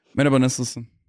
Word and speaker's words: Merhaba nasılsın: So how do you say hey? How are you? – Merhaba nasılsın. – I'm sorry. Say that again. – Merhaba nasılsın. – Merhaba. – Yeah. – Nasılsın. Sounds like you're stuttering. – Nasılsın. – Nasılsın. – No, I'm not. That's how you say it.